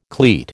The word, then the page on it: cleat: American English